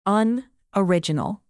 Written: unoriginal

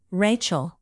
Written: rachael

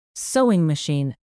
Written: sewing machine

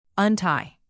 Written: untie

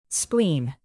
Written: spleen

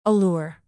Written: allure

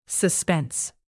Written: suspense

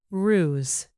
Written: ruse

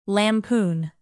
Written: lampoon

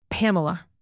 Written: pamela

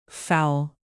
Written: fowl

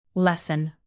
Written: lesson

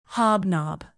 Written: hobnob

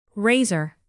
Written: razor

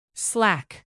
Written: slack

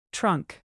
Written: trunk

